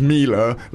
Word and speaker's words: Miele: it's Miele, like Sheila and car dealer."